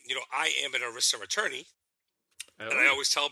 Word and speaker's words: ERISA: I know I said it wrong. – ERISA. – ERISA. Thank you. Thank you. it doesn't spell that way to my thinking, but ERISA, which is the employment.